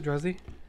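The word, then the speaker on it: Druzy: Is that how you pronounce it? Druzzy? – Yeah.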